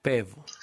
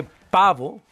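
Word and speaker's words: Pääbo: Paabo. – Muy bien. Porque ¿sí? Se escribe, ahora sí que Paabo, pero con doble A y cada A tiene dos diéresis.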